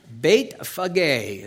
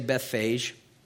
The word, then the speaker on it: Bethphage: Verse 1 says, "Now when they drew near to Jerusalem and came to Bethphage," is how that's pronounced, but you can say Bethphage, you know, "at the Mount of Olives, then Jesus sent two disciples."